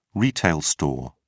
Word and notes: retail store: British English

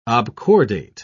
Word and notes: obcordate: British English

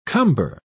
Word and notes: cumber: British English

